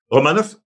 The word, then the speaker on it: Romanov: Alexander Romanov, ça, c'est un peu plus compliqué parce que Romanov, en principe, on devrait insister sur la deuxième syllabe, mais on est habitué ici, comme je te donne l'exemple, tu sais, Kovalev, à l'époque, moi, je disais, Kovalev. Alors, c'était sur le ko qui on mettait l'accent, mais là,